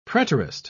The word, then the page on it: preterist: British English